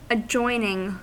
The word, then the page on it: adjacent: /ad.ʒa.sɑ̃/; France